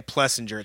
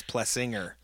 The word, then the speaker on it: Plessinger: See, I knew it was Plessinger. Everybody else is saying it wrong. All you fucks, stop saying Plessinger. It's Plessinger.